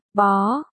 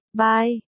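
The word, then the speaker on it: bó (柏): Tên của anh Bách là Bạch, bye, bó, nhưng được đọc là bó, chứ không phải bye, như bạch cách chương.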